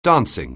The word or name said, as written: dancing